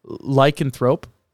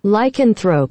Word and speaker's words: Lycanthrope: Lycanthrope. – Lycanthrope. Hey, there you go. You got I, that. I got it kind of right. I put the right emphasis on the wrong syllable.